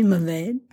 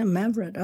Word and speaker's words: Mavrud: International Mavrud. Is that supposed to be Mavade? – Nope. – No, Mavrud.